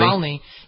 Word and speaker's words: Navalny: Navalny? – Navalny.